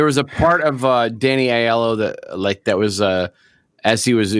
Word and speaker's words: Aiello: Aiello.